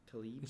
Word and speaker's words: Tlaib: Rashida, how do you pronounce her last name? – Tlaib. It's spelled Tlaib.